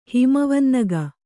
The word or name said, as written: ಹಿಮವನ್ನಗ